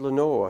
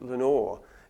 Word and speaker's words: Lenore: And you keep thinking, well, when is the lost Lenore, who rhymes with Nevermore and whose name is mentioned, through rhyme after rhyme, in stanza after stanza, when is she going to come back?